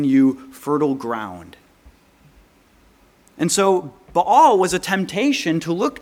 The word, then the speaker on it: Baal: You see, if you don't know anything about the god Baal, or Baal, as sometimes we pronounce it, this was the god of fertility.